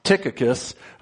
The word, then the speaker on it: Tychicus: Starts off Tychicus, which actually the CH there is pronounced like a K. So we have Tychicus, a beloved brother, faithful minister, and fellow servant in the Lord, will tell you all the news about me.